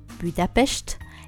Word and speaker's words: Budapest: Il faut savoir que Budapest, qui se prononce Budapest, est composé en deux parties.